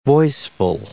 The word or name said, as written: voiceful